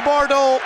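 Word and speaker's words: Bourdeau: Remy Bordeaux is in. It's pronounced the same as the town of Bordeaux.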